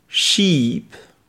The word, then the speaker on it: sheep: Vous avez sheep, qui est un mouton. Et ship, qui est un bateau. Donc je répète les deux mots. Sheep, ship.